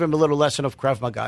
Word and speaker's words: Krav Maga: So Krav Maga is an Israeli-style self-defense, which everybody in the Israeli army actually goes through.